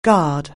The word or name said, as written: guard